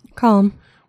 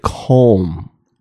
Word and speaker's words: Calm: because I feel like you just tiny bit pronounce the L. Everyone say the word Calm. Well, Calm. – But no, it's more you said it Calm.